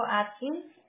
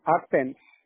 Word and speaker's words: Athens: Madison, Athens, Atkins. – Athens. – No le escucho bien, ¿me dijo Atkins? – No, Athens.